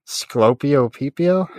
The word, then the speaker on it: Sclopio Peepio: Is there no L? No, there's. It's sc- Sclopio, S C L O P I O.